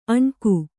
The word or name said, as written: ಅಣ್ಕು